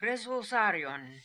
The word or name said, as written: Resulzarion